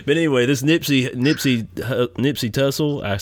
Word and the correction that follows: Hussle: It's Hussle. It's like Hussle and Float.